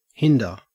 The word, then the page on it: hinder: Australian English